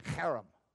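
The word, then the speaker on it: cherem (חרם): That C-H is pronounced. If you have a cold, don't try to pronounce it. Don't want any accidents. Repeat after me, cherem.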